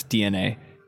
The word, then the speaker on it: DeNA: But when you see DeNA, why are you ignoring DNA. – The E? It's there. – It's part of the word.